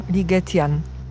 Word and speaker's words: Ligetian: I tak powstał Ligetian. Tak można to wymawiać? – Tak.